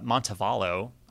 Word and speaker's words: Montevallo: What's really cool is that his album, Montevallo, I could be pronouncing it wrong. – I think that's right.